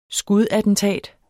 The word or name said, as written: skudattentat